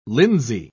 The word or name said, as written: linsey